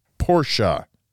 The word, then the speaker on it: Porsche: It's pronounced Porsche. – Porsche.